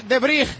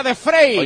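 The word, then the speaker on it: de Vrij: Indy en horizontal para De Brij, le conocemos como De Brij, pero se pronuncia De Frey.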